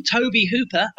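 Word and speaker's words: Tobe Hooper: At the same time, Toby Hooper, as we found out it's pronounced, was trying to follow up the success of The Texas Chainsaw Massacre.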